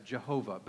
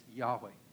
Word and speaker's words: Yahweh: In older times, it was translated as Jehovah, but a better way of pronouncing it is Yahweh.